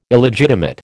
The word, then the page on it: Illegitimate: American English